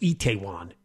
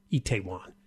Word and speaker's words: Itaewon: It's pronounced Itaewon, Itaewon.